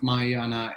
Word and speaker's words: Mahayana: And then Sarah is trained in a Tibetan language lineage of Buddhism and practices both Mahayana and Vajrayana Buddhism. Did I even get that close? It's fairly... – Yep, that was good pronunciation. Perfect.